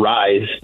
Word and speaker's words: Ryz: Yeah, we came out with the Tetra Rise. It's spelled RYZ, all capital letters, but pronounced Rise just like a rising sun.